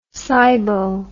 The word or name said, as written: sybo